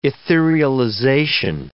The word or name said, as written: etherealization